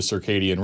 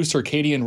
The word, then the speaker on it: circadian: There is real science behind the idea of the circadian rhythm and how important it is. None of us follow a true circadian rhythm anyway. – Circadian. Stop saying it wrong. Circadian. Both of you.